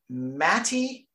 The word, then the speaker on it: Mate: When you read it in English, it reads Mate Rimac, like we're used to pronouncing Rimac, but apparently the formerly Yugoslavian born, now, you know, Bosnia, Herzegovina, Croatia, you know, it's like changed names like 10 times. It's pronounced Matty.